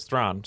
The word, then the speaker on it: Thrawn: Right, because so basically the name is spelled sort of what you would be familiar with as like Charlize Theron, or how we say it here, probably incorrectly, but the correct pronunciation is Thrawn.